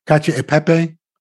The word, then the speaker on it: cacio e pepe: And he made it, I always mispronounce it, it's easier, cacio e pepe. – Yeah, that's not mispronounced.